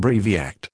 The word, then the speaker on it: Briviact: This is the consumer medicine information for Briviact, pronounced Briviact, injection for solution, active ingredient, S, Brivaracetam, Brivaracetam.